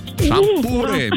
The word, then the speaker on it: шампуры: Итак, запомните, Олечка, и вы особенно, что везде, как бы вы там ни переворачивали, ударение во всех формах существительного падает на букву «у». Шампуры, металлические шампуры, жарить на шампуре, есть с шампура.